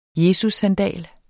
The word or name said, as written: jesussandal